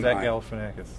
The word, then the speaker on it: Zach Galifianakis: The go ahead, because you're better at pronouncing his name than Zach Galifianakis.